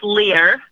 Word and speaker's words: Lehr: It's officially pronounced Lear, but it looks like Lair, L-E-H-R.